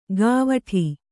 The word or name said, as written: ಗಾವಠಿ